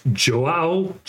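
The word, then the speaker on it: João: I think it's Joao. I don't know how you say Joa. – His name. – Joa. – Okay. So you don't pronounce that last O.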